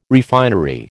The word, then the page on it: refinery: American English